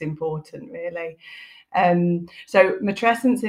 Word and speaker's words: matrescence: Yes, you have said it properly. I pronounce it matrescence.